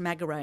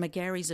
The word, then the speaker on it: McGarry: The M stands for McGarry, which I always pronounced Magaray, and I knew that's wrong. And McGarry's a very Adelaide word, absolutely.